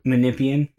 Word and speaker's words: Menippean: I'm not sure if it's pronounced Menippean or Menippean, but Menippean. Okay.